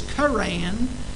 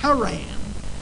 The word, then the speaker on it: Haran: The God of glory appeared unto our father Abraham when he was in Macedonia before he dwelt in, and the pronunciation here is Karan, but in the Hebrew, from where we get our Old Testament, they use the terminology Haran.